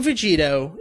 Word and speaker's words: Vegito: The name Vegito is a combination of Kakaroto and Vegeta. Now, you take the Vegi and the To, it's a hard To sound at the end there.